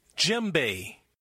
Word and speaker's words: djembe: Djembe. Djembe, pronounced with a silent D.